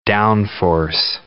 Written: downforce

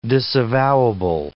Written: disavowable

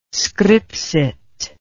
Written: scripsit

